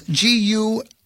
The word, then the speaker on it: Guilbert: Somebody by the name of Ann Morgan, I believe it's pronounced Guibert. – Yeah, Rudy's mom. – Close. Guilbert. Actress.